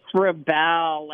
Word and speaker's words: Rebel: Is it Rebel or Rebel? – It's Rebel, like the verb.